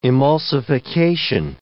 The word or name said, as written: emulsification